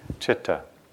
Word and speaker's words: citta: It's spelled C-I-T-T-A, and it's pronounced citta.